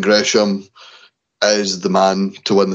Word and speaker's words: Gresham: Jonathan Griesman, or Griesham, am I pronouncing that right? – Gresham.